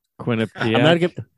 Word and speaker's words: Quinnipiac: Quinnipiac. – Quinnipiac, is that or Connecticut? – Yeah. The one somewhere on the East Coast. We don't know where it is. – Quinnipiac, that's got to be New Jersey.